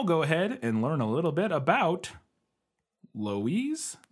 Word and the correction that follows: Lois: I think it's Lois. It's spelled L O I S, and I'm pretty sure that's just Lois.